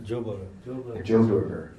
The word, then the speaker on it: Joburger: A Johannesburgian? How do you say that? – Joburger. – A Joburger, okay.